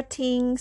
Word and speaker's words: 厅: The next character ting starting with T as a consonant pronounced as t and the vowel is ing pronounced as ing tho with the first tone above letter I.